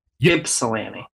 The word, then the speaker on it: Ypsilanti: You don't pronounce the Y. Ypsilanti.